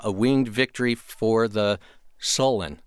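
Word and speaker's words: A Winged Victory for the Sullen: This is music from A Winged Victory for the Solen.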